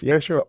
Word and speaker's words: Yeshua: It is true that in Hebrew, the name is pronounced Yeshua or Joshua.